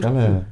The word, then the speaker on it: Kelleher: Kelleher.